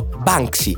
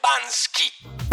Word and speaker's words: Banksy: Il nome è chiaro, Banksy. – Anche se noi in Italia non so perché lo pronunciamo spesso come Bansky.